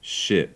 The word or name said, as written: ship